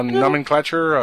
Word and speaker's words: nomenclature: What did you call it? Nanoclature. – yeah, I, I kind of butchered that one. Nanom, nomenclature.